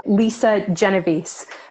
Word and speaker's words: Lisa Genovese: So, Lisa Genovese. Uh, my last name is obviously Italian, but I gave up a long time ago on uh, people getting it right, Genovese.